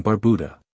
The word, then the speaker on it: Barbuda: About Antigua and Barbuda. Antigua, pronounced Antigua, and Barbuda, Barbuda, is located in the heart of the Caribbean Sea.